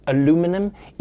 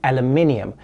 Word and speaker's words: aluminium: By the way, for the rest of the segment, I'm gonna be pronouncing it aluminum, even though the correct pronunciation is aluminium.